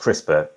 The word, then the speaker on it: CRISPR: It's spelled crisp and then R at the end. It's pronounced CRISPR. CRISPR.